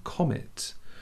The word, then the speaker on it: Commette: would hit the home of a family with the surname Comet. Now, it's a French name. It's spelled C-O-M-M-E-T-T-E.